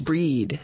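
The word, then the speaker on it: Brigid: It looks like Bridget, but I guess it's pronounced Breed, Saint Breed's Day.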